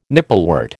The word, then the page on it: nipplewort: American English